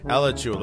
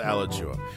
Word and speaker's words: Alachua: So the winning link is an La Cachua. I can never pronounce half of the freaking counties in the state of Florida. – Alachua. – Let's go with Alachua.